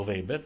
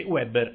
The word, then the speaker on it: Weber: Vi prego di pronunciarlo Weber, perché sono piccole cose, piccole idiosincrasie, perché Weber è quello che ha scritto Jesus Christ Superstar e non ci interessa.